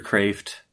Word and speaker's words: Kreeft: Other people to read, if you're sort of intro philosophy, Peter Kreeft, K-R-E-E-F-T. I know people pronounce it all sorts of different ways. I've heard him say Kreeft, so I'm sticking with Kreeft.